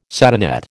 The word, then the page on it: satinette: American English